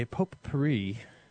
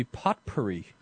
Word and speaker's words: potpourri: Well, today's show is a potpourri. I don't know why the word potpourri has all these T's in it, and that you don't hear a single one of them.